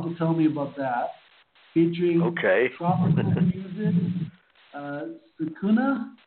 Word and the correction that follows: Sazonk: Well, let me first of all straighten that one out. It's Sazonk, which is actually a combination of three musical terms, salsa, jazz, and funk, hence the word Sazonk.